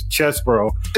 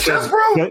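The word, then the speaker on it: Chesebro: they don't know how to pronounce his name until his attorney said it. So it's Chesbro. – It is Chesbro.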